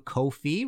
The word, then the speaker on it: Ko-fi: If you like this show and want to support future episodes, you can go to Ko-fi, rhymes with no fee, .com/ Martin Popoff, hit that red support button and buy me a coffee or a pint.